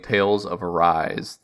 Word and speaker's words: Tales of Arise: Why don't you tell us about this Talus of Arisae? Am I pronouncing that right? – Close. I have been playing Tales of Arise